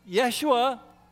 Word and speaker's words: Yeshua: And so when he was a boy and his mother called him in for dinner, she might say, Yeshua, Yeshua. That's how they said his name.